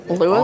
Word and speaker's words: Lewes: Lewis? Awesome. You were struggling with Lewis? – Well, it's spelled not a way that you would think. – L-E-W-E-S.